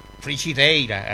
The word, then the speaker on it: frigideira: frigideira, así se pronuncia, claro.